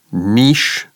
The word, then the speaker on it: niche: The word niche comes from French. That's why that's the way we pronounce it. You will find a few Americans that pronounce it the way Campbell did. I would say niche is the more common pronunciation.